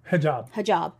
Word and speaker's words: hijab: because I think Americans tend to think of hijab. Is that how you say it? – Hajj. – No, the covering. – Hijab.